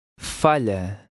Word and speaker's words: falha: Now look at number 16, which has an LH combination. This is pronounced like LY. Try to read number 16. – Falha.